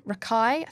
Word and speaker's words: Rakai: So we've got Jordan Rakai, I think you said you pronounced it. I can say Rakai.